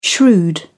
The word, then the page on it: shrewd: British English